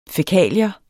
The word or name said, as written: fækalier